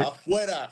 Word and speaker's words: Afuera: So, Afuera, is that right? – Afuera. – Afuera.